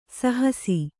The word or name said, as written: ಸಹಸ